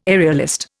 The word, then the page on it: aerialist: Indian